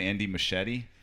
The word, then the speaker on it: Andy Muschietti: Andy Muschietti.